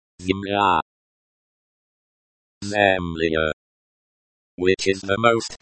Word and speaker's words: Земля: Pronunciation. Земля. Note the stress is on the second syllable.